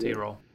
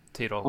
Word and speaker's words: Tirol: He's, he's T-roll. A, um, oh, T-roll. Is that how you pronounce it? Okay. T-roll, okay.